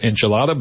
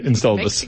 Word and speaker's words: Enceladus: Some people pronounce it Enchilada, but. – Sure. – Yeah, Enceladus. – Enceladus.